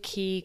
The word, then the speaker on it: Caye: And in Belize City, we were catching a ferry over to Caye, Caulker. So the Keys, they spell them here C-A-Y-E, like K, but they're pronounced Keys.